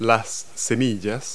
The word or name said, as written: seeds